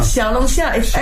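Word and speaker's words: 小龙虾: How do I say that? Xiaolongxia. – Xiaolongxia.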